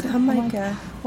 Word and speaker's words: jamaica: It's spelled like Jamaica, and they pronounce it Jamaica.